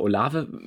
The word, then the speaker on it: Olave: okay, nach dem schönen Déjà-vu, also genau, der Name Olave wird so ausgesprochen und er hat gefragt, ähm, ob der Offensive Coordinator, der O-Line-Coach, was zu den Protection Calls gesagt hat.